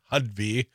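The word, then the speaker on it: Hovde: Well, when you talk about the best and brightest from Wisconsin, I'm less clear that either Eric Hudby, and I've learned, I heard his ad last night, it's Hudby. – Hudby, yes. – I was calling him Hudby, but, you know, yeah, emphasis on the ho, like sellout, you know, Laguna Beach sellout ho.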